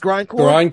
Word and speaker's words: grindcore: because they love their grind gore, right? Am I saying it right, or am I saying it wrong? It's grindcore.